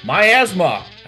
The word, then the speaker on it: miasma: I've got to tell us about the miasma. I got that right? – That is miasma. It's miasma. Also, I mean, some people call it miasma, miasma, but it's definitely pronounced miasma.